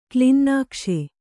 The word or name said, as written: ಕ್ಲಿನ್ನಾಕ್ಷ